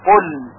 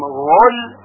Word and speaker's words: قل: توجه دارید که قل با قاف نوشته میشه و باید با قاف هم خونده بشه قل، پس اگر با غین بخونیم و بگوییم قل این غلط هست.